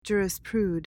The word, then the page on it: jurisprude: JOOR-uhs-prood